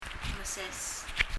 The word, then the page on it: meses: [mɛses]